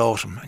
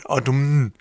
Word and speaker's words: autumn: And you were going, where did that N come from? And then when they introduced you to the adjective, autumnal, there's the N. Why can't we pronounce it in the ordinary word? Autumn, autumn.